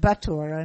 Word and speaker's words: Bottura: I want to make sure that we're spelling, that everybody understands that Massimo's name is B-O-T-T-U-R-A. My sloppy pronunciation said Bottura, and it's Bottura.